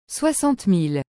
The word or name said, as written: 60000